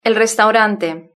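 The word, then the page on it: el restaurante: Spanish (Spain)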